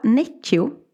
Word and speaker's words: Necchi: Eh, jag kan inte uttala, men det heter Villa Necchio. N-E-C-C-H-I.